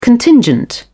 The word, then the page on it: contingent: British English